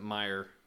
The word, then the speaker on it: Mayer: It's M-A-Y-E-R, Mayer, but they pronounce it Meyer. Yeah. My baloney has a first name, it's O-C-E-R.